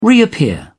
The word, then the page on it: reappear: British English